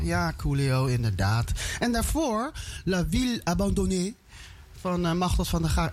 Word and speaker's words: La Ville Abandonnée: Ik hoop echt dat ik het goed heb uitgesproken. – Dat spreek jij mooi Frans. – Ja, het zei ze heel Frans. – La Ville Abandonnée.